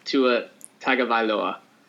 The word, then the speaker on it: Tagovailoa: So with the 5th pick, the Bengals select Tua Tagovailoa.